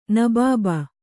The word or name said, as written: ನಬಾಬ